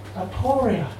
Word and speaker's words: aporia: Aporia, aporia, aporia, a recognition of impasse, of an ebbing of resources, and of puzzlement or doubt. Aporia, aporia, aporia, aptly rhymes with gloria, expression of remote lineage, which it gives me joy to find.